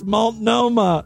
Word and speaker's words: Multnomah: How does she pronounce that? – Multnomah County... – Multnomah. I guess I... That sounds like a cancer. Oh, God. The doctor said it's Multnomah.